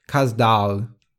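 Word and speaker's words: Kaz Dağı: The Mount Ida in Turkey, which of course is the one in this story, is pronounced in Turkish Kazdağ.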